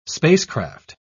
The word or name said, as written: spacecraft